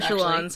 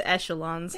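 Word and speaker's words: echelon: I don't know how to pronounce that word, echelons. – Actually. – I think it's echelons. – Thank you. – It's the fancy like, soft CH.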